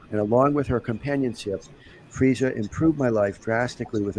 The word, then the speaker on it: Freesia: "My dog Freesia, pronounced Freesia like the flower, was a beautiful golden Labrador retriever with the softest ears and sweetest face.